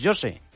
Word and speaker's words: José: Pero José, en portugués, José.